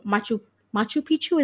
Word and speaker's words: Machu Picchu: So I really want to see Machu Picchu. Is that how you pronounce it? – Yeah, correct.